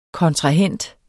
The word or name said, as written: KONTRAHENT